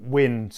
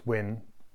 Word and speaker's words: UYN: We talked about it before, but Wyn, spelt UYN, which I read is now Unleash Your Nature. I'm still not 100% sure why it's pronounced Wyn, even though I've been over there, didn't really find that out.